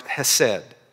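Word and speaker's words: hesed (חסד): The Hebrew word for kindness here, which is found over 250 times in the Old Testament, is pronounced hesed.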